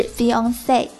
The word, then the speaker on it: fiancé: Fiancé F-I-A-N-C-E 表示未婚夫，未婚妻的发音与未婚夫的发音完全相同，都是 Fiancé，但是呢，未婚妻的英文比未婚夫的英文结尾多了个字母 E。